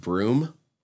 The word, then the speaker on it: Bruttenholm: And it's kind of like in Hellboy, if you've only ever read it, you probably wouldn't realize that the professor's last name is pronounced Broom. Even though it's Bruttenholm.